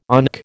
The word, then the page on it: ONC: American English